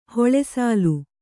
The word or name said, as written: ಹಲುಸಲ್ಲೆ